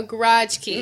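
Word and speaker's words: grachki: It's spelled G-R-A-C-H-K-I, a garage key.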